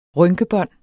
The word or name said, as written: rynkebånd